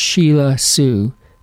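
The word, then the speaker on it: Selah Sue: Her name is, I think it's pronounced Sheila Sue, though it's spelled S-E-L-A-H, and she's from Belgium. So there's an export besides chocolate, right? And waffles. You've got Sheila Sue as well.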